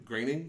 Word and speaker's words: Groening: So good on you, The Simpsons, and the creators of The Simpsons, and Matt Groening, and is that how you say his name? Groening? – Yes. – They're so fucking Germans. – It looks like Groening, but it's Groening.